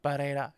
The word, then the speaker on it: Barrera: I learned is how you pronounce his last name. My girlfriend has reassured me that it's Barrera instead of Barrera. So I have to make sure I do that.